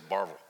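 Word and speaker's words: Barbourville: I was born in Detroit, but grew up in a small town in Kentucky called Barberville. Like saying Barberville of the nations, or we said Barville. They didn't do three syllables where I grew up.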